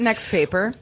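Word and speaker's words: NXTPAPER: TLC also announced the NXTPA, this next paper, I think is how it's pronounced, 14 Probably. – Pro. – Probably, next paper, yeah.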